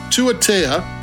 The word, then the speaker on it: Tuatea: They have a pronunciation thing in the bottom, and I keep on forgetting it. Tuatea.